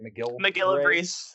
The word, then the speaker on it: MacGillivray: But the MacGillivray, is that how you say it? – MacGillivray Seaside Sparrow.